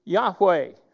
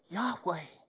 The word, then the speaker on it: Yahweh: Now we've come to Yahweh, we don't really know for sure how it's pronounced, but that's kind of how we pronounce it today instead of Jehovah. Yahweh.